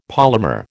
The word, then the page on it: polymer: American English